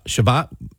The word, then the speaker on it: Shabbat: Shabbat.